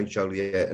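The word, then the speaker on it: Joliet: I was doing that thing where the French wouldn't pronounce the T at one point, and I was like, Joliette. – No, we pretty much in America, we do say Joliette, and it's obviously named after the French explorer.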